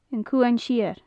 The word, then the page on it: an cuan siar: gaelic